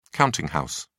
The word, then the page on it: counting house: British English